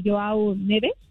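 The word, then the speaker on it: João Neves: Pero más se desató por las declaraciones que dio precisamente Joao Neves, si lo pronuncié bien.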